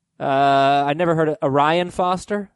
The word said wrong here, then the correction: Arian Foster: Arian Foster.